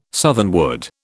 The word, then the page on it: southernwood: British English